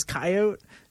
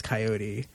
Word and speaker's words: coyote: Every Native Westerner that I've met and talked about it with says coyote. Every Native Easterner that I've talked about it with says coyote.